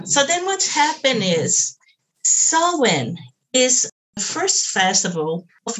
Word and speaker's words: Samhain: You pronounce it Samhain, but you spell S-A-M-H-A-I-N. Samhain. you spell it S-A-M. H-A-I-N. Uh-huh. You pronounce it Samhain. – Okay, so you have some silent consonants in there.